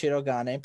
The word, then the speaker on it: Shirogane: And it's pronounced Shirogane.